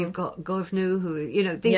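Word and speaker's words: Goibniu: I'm just using Ella Young's pronunciation yeah. – there. It is Govnu, Lochta, Kretna.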